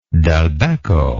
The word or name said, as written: Dulbecco